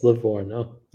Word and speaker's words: Livorno: But yes, it's Livorno.